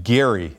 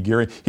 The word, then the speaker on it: Gerry: Seth's at Denver, points out that Elbridge Gerry, his proper pronunciation is actually Gerry. And so we've been saying gerrymander for years, and it should be Gerry.